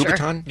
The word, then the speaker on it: Louboutin: Have to wear a green velvet sweater with orange suede pants, Louboutin. Is that how you pronounce it, Ellie? – Sure. – Louboutin yep.